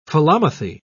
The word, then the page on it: philomathy: British English